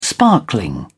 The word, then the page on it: sparkling: British English